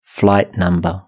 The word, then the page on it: flight number: British English